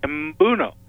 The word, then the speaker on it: Mbuno: So well, you know, you read the name Mbuno, and Mbuno, and people go, how the heck do I pronounce that? And I say, just do it phonetically, Mbuno.